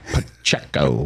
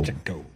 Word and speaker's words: Pacheco: And by the way, is there anybody who says every syllable of one person's name quite like Jim Nantz says Pacheco? he lays into that Pacheco. Pacheco. I mean, every syllable is just nailed by Jim Nantz when he says Isaiah Pacheco's name.